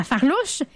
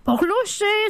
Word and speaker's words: farlouche: La tarte à la farlouche, qu'on prononce de bien des façons la farlouche, la porlouche, ça dépend des régions.